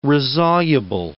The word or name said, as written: resoluble